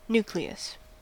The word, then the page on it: nucleus: American English